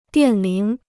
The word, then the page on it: 电铃: diàn líng